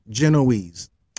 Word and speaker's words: Genoese: A joint Genoese, and I'm sure I'm pronouncing that wrong. Let me just see if I can put some respect on that name. Not that I necessarily care, but I'm just curious because I came across that problem the other time. Pronunciation. Here we go. Let me see what this says. Okay, here we go. Genoese. I'm sorry. There we go. Genoese.